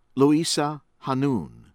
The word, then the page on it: Louisa Hanoune: American English